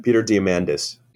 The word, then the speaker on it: Diamandis: Because same author, same intellectual, I guess, whatever, Peter Diamandis. Is that how you say his name? – Peter Diamandis.